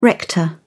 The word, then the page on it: rector: British English